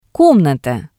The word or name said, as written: komnata